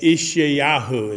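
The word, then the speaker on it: Yeshayahu: Ishayahu is how you actually pronounce it in the Hebrew.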